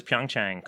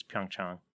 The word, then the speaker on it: Pyeongchang: Everyone says Pyeongchang because it's spelled that way in English, but it's Pyeongchang.